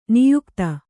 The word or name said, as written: ನಾಯಕಿತಿ